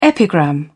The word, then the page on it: epigram: British English